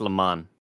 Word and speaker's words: Le Mans: It's spelled, like, Lee and then Mans. – Yeah. – But it's Le Mans.